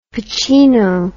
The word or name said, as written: pacino